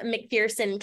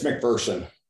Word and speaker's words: McPherson: Joining me on the show are Beyond Strength out of McPherson, Kansas. Welcome to the show, guys. How are you today? – What's that? – It's McPherson.